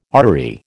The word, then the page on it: artery: American English